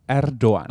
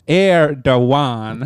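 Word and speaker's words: Erdoğan: Och på turkiska så ska man säga Erdogan. – Ah, okej, okay. Så det är ett silent G. – Så det försvinner nästan helt hållet. – Ett tyst G.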